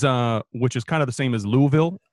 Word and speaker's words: Louisville: It's the vul. Louisville. – It's like almost one syllable, barely two. Louisville.